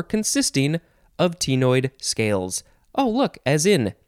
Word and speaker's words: ctenoid: Next is, you can pronounce it a couple of ways. I want to say tenoid. You could also just say, instead of T, just tenoid, I think. Give or take. I like tenoid. It is spelled C-T-E-N-O-I-D.